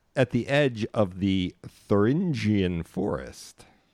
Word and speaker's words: Thuringian: It's spelled, pronounced just like it's spelled, Thuringian.